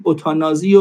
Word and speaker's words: اتانازی: حالا تو فارسی نوشتیم اتانازی و همینجور هم تلفظش میکنیم.